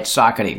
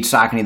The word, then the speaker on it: Saucony: So Saucony, we've given indication how to pronounce it. Right, Saucony. We hear all kinds of variations. We do not correct people. They can say it however they want, but it is indeed Saucony.